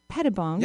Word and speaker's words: Pettibone: And so we have Mimi Pettibone.